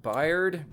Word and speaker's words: Baird: I believe his last name is pronounced Baird or Bayard. – B-A-I-R-D? – Yes, Baird.